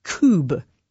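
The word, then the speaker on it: Kubb: I'm told it is cube.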